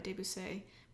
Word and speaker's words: Debussy: I have no idea Debussy. Who are you? No, it's Debussy. He was French. – I don't know. I was like, I always kept thinking of, like, a French person being like, Debussy.